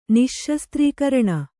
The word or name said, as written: ನಿಶ್ಶಸ್ತ್ರೀಕರಣ